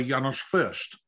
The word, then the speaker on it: Janos Fürst: I read that you were taught by Janos Fürst, that's how you pronounce his name.